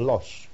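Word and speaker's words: Loos: and then we proceeded from Lons to a suburb of Lille called Luz, but the French pronounce it as Los.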